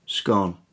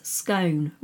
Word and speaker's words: scone: Scone. – See, I knew this was going to happen. It's scone, rhymes with stone. – Nope, it's scone, rhymes with gone.